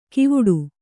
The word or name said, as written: ಕಿವುಡು